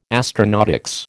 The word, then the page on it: astronautics: American English